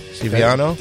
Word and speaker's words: Stiviano: Stiviano, is that how you say it? Stiviano? – That's how I say it.